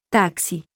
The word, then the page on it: x: letter; Spanish alphabet